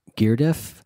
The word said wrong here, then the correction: Gurdjieff: was Gurdjieff.